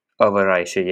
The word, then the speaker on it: Overijse: 197 kilometers stage from Leuven to Overijse. Is that how you say it? Overijse, yeah, I had a bang on.